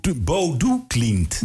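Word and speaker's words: Bodø Glimt: Oh ja, je moet dat heel gek uitspreken. – Het is Bodo Glimt. – Maar het is een O met een streepje erdoor. – Ja, dus ik dacht, net als bij Bluff.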